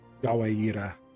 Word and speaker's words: Gwaer: He'll spell it the same way, but he pronounces it Gawaira. – Gawaira.